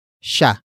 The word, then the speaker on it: siya: The next word is sha, which is spelled as S-I-Y-A.